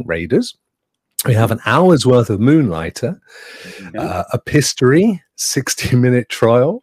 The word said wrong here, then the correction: Epistory: I, I, I know it's epistory, but whatever.